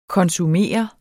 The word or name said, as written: konsumere